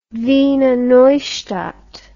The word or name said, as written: Wiener Neustadt